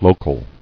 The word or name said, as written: Locally